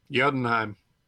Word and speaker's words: Jotunheim: So Jotunheim.